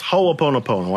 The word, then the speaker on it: Ho'oponopono: But what you were referring to is a Hawaiian spiritual system called Ho'oponopono. Don't have to remember it. Don't have to spell it. Don't have to say it. But it's Ho'oponopono.